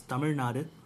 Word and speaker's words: Tamil Nadu: The actual pronunciation is Tamil Nadu.